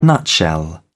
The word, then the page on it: nutshell: British English